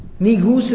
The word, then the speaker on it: Negus: Negus is the most appropriate pronunciation of it. It's the proper pronunciation of it. That was Negus. Spelled N-E-G-U-S. Negus.